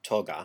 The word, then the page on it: client: /ˈklaɪ.ənt/; American English